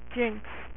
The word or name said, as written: Jinx